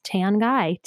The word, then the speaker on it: Tanguy: but my last name is Tanguy, T-A-N-G-U-Y. And I spell Tricia with C-I-A, not S-H-A.